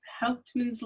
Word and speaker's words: Hauptmann: Your first book was Hauptmann's Ladder.